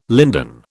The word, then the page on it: linden: British English